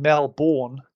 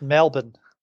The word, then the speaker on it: Melbourne: You're not saying Melbourne. – Yeah, it's just Melbourne.